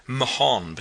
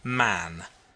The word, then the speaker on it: Mahon: He had a Roman nose, a snow-white long beard, and his name was Mahon, but he insisted that it should be pronounced Man.